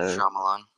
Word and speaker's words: Shyamalan: I never know how to say his last name, so Shyamalan. – but yeah, that's it. Thank you.